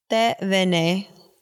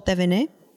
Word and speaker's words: Thévenet: I never know how to pronounce his last name. Thévenet. Jean-Paul Thévenet.